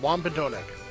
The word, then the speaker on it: Wampanoag: Okay, she says yes, you are saying it right. It's Wampanoag. Okay.